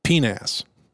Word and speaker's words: PNAS: which is PNAS, or as they pronounce it, PNAS.